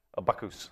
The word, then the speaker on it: Bacchus: A Bacchus. Uh, we, we follow the, the, French enunciation of the term.